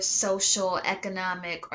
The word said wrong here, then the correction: socioeconomic: or sorry guys, I said that wrong, socioeconomic status?